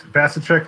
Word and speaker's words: Vasicek: That's correct. Vasicek like cash a check. – Outstanding. So can you introduce yourself to the audience? – Yes, my name is Joe Vasicek.